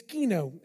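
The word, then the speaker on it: skeno (σκηνόω): The Greek word for that is skeno, and I know that's how you pronounce that.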